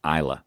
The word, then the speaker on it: Islay: We uh, went to the Mull of Kintyre, went to Machrihanish and went to the Isle of um, uh, called Islay, which is spelled I S L A Y.